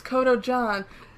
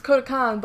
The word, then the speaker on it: Kodokan: It's Kodojan, but it's actually Kodokan, but it's spelled Kodojan. That's not right.